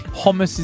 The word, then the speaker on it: hummus: That's how you meant to say omas, is how you pronounce it properly. Hummus is the Australian version. It's omas.